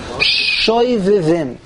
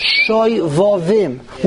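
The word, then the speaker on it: Shovavim: First of all, most people pronounce it, I've discovered, Shoivivim. There's no such word, Shoivivim. It comes from a pasuk in Yirmiyah. There really are two psukim. Shuvu vonim Shoivavim.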